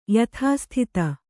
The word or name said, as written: ಯಥಾಸ್ಥಿತ